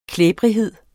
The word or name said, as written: klæbrighed